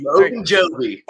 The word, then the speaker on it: Ogunjobi: Ogunjobi.